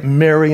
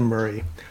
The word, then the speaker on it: Murrey: I get Mary instead of Murray.